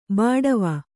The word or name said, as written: ಬಾಡವ